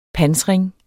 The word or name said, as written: pansring